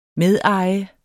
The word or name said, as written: medeje